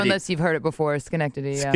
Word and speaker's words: Schenectady: Schnecktady.